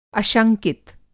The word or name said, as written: अशंकित